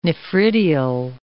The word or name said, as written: nephridial